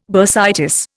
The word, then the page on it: bursitis: Indian